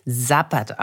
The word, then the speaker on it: Zapad: Ich hatte es Zapad genannt, es spricht sich aber, wie ich jetzt gelernt hatte, Zapad aus.